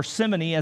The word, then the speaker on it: simony: The church down through the ages has put a Y on the end of Simon's name, and simony, or simony as it's sometimes pronounced,